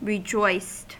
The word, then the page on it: rejoiced: American English